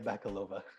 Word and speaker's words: Bakalova: All right, Maria Bacala. How do you pronounce the last name? You said it before. – Maria Bakalova. – Maria Bakalova. Okay, thank you.